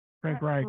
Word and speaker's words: Frank Reich: Fucking, how do you say his name? Frank Wright? – Frank Reich. – Reich. – Frank Reich. – Sorry. – Yeah. – Like Heidenreich.